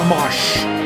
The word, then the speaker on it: Amash: Justin Amish. How's it pronounced? – I say Amash. I might be wrong, though. – Amash. Justin Amish. Amash.